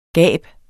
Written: gab

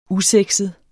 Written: usexet

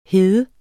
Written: hed

